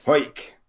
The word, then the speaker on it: Hawick: Yep, Hoyk. – Of course, indeed. – That's right. It's pronounced, is it Hawick? – Yes. – Hoyk.